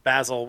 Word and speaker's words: Basil: I mean, I think they pretty much universally pronounce it Basil, which right. is even funnier.